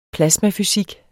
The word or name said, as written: plasmafysik